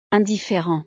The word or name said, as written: indifferent